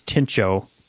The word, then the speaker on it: Tincho: We're not sure how to pronounce it, but we think it's Tincho, T-I-N-C-H-O. So that's what we're going with.